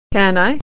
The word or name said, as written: can